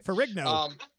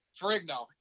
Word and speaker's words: Ferrigno: Ferrigno. – Ferrigno.